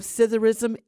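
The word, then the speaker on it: psithurism: Like many words that begin with PS, the P at the beginning of scytherism is silent. Scytherism.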